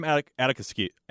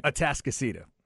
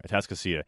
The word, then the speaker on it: Atascocita: And as Puts number 13 responded on the Specs text line, talking about Nate Kibble, he's from Atascocita. Did I say that right? – Atascocita. – Still learning, guys. I'm sorry. Atascocita.